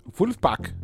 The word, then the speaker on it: Vulfpeck: They spell it phonetically. – Okay. – And I'm going to pronounce it the way it's spelled. – Okay. – Wolfpack. – Wolfpack. Yeah, that's good. – Instead yeah. – of, you know, so it's a play on Wolfpack. – Right. – But it's spelled V-U-L-F-P-E-C-K. This is a new release.